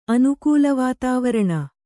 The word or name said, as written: ಅನುಕೂಲವಾತಾವರಣ